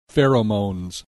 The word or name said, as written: pheromones